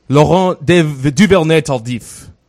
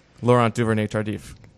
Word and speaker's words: Laurent Duvernay-Tardif: as I pronounce with my dirty French accent, Laurent Duvernay-Tardif. Laurent Duvernay-Tardif.